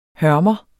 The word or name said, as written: hørmer